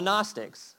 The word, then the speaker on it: Gnostics: Everyone say, the Gnostics. It was spelled with a G, G-N.